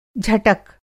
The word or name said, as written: झटक